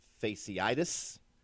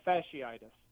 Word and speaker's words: fasciitis: Plantar fasciitis? – Close. Very, very close. Commendable. – Okay. – But not completely correct. It's plantar fasciitis.